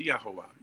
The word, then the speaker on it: Yahuah: When you were talking about piercing the name, when you look in the Sefer and you're phonetically writing in Y-A-H-U-A-H, in other words, this is how to help you pronounce it. In other words, Yahuwah, or Yahuwah.